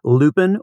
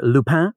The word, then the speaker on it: Lupin: There's a very popular French show that some folks have probably watched on Netflix, Lupin, or Lupin, if you want to go with the really French pronunciation.